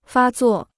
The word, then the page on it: 发作: fā zuò